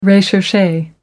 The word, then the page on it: recherche: American English